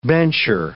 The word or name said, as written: bencher